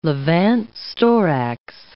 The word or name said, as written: Levant storax